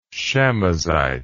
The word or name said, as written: chamosite